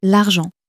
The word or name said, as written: argent